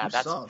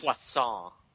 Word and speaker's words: puissant: Yeah, puissant. That's puissant.